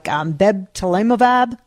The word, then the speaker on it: bebtelovimab: I'm going to say it wrong, like bebtelovimab, something like that. – Wow, you did fantastic. – Was I close? – You did it exactly right.